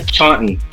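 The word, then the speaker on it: Charlotte: Doutor Nosso, falou aí, como é que pronuncia aqui, Talto aqui? Talto é minha cidade onde eu moro. Eu falo só Talto. Mas os americanos falam assim, é Chorten. – Chorten. – Não fala Talto, não, fala Chorten.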